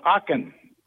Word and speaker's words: Aachen: In the cathedral in Aachen. – Spell it. – A-A-C-H-E-N, Germany.